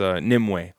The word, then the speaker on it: Nimue: No, it's Nimue.